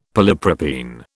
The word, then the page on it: polypropene: British English